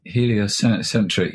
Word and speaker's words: Heliocentric: Heliocentric.